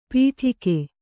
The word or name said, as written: ಪಿಡಿಕೆ